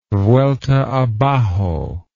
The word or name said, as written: vuelta abajo